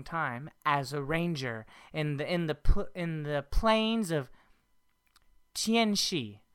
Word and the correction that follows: Tianxia: How do you say that? Is that right? – I said we call it Tianxia. – Tianxia. She spent a long portion of her life as a ranger guiding people across the plains in Tianxia.